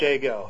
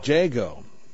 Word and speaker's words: Jagow: I like to say if you're bird watching, you say, hey, look at that blue jay go. – Ah. – So it's Jago. – Jago.